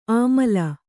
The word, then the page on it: ಆಮಲ: Kannada